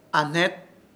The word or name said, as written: AHNET